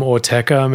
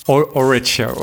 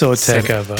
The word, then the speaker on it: Autechre: See, you're pronouncing it correctly. I've always called them like Orecha or something. I know I've seen that name written down, but never like. – I think it's Orteca.